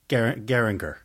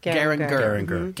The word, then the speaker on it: Geiranger: Geiranger. – Geiranger. – Geiranger.